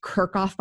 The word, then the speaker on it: Kirkhoff: Try teaching middle school, you know, Kirkhoff, doesn't that rhyme with? No. – I love that. So it's perfect. – Yes. I told him what I had to speak at a educational conference. And I said, so I'm just going to get up there and say, hey, I'm Jen Kirkhoff. Doesn't rhyme with anything. Oh, no. I'm Jen Kirkhoff. Rhymes jerk off.